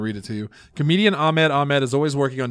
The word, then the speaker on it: Ahmed: I hope I'm pronouncing that correctly. Because it's A-H-M-E-D. That's Ahmed, right? – Surely.